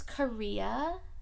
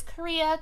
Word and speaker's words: Khayriyyah: My name is Kahria Cutno. Spell it. – K-H-A-Y-R-I-Y-Y-A-H. Okay, no, they don't need all that. My name is Kahria Cutno, and I am a mom.